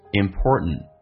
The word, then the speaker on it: important: 我们来看一下，首先其中有一个实义词汇叫 important， t 跟 n 在一起的时候，或者 n 跟 t 在一起的时候，那么 t 都可以不发音，那这边通常口语化当中不会说成 important，会变成 important， t 不发音，第一个 t 最后一个 t 也不发音，